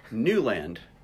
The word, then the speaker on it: Newland: Newland. – Yeah. Oh, it's Newland. – Guy Newland, yeah. Uh-huh. – Yeah. I thought it was Newman. – I know a lot of people think that.